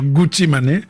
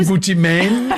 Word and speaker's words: Gucci Mane: Lui, s'enthousiasme pour Gucci Mane. Prononciation non garantie. – Voilà, Gucci Mane. – Gucci Mane, voilà. – Gucci Mane. Ou Gucci Mane en anglais.